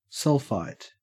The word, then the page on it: sulphite: Australian English